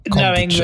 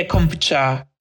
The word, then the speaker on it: kombucha: So it's a short U, kombucha. – That, yeah, kombucha. Yeah, okay. Kombucha, because all their syllables are short in Japanese.